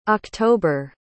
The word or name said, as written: oct